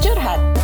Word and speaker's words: curhat: Nope. It's pronounced curhat. It's an Indonesian term.